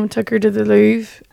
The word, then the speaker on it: Louvre: Louvre, I think, is how you want to say it.